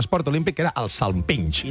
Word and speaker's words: salpinx: Això és el salpinx. No sé ni com pronunciar-ho. – Salpinx. – Amb X final.